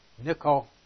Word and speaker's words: Nikolaos: Nikolaos. I mean, a couple of problems there. It's a short O and not a long O, and the Laos is not in the accusative case.